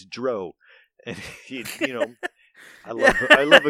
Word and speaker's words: drow: Who invented drow? Oh, God. – Beep. – Let's see. No, I'm fine. The official second edition drow handbook states it rhymes with cow and how.